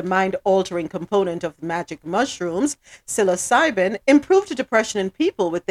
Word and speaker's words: psilocybin: So the psilocybin, am I saying it right now? Psilocybin. – Yes.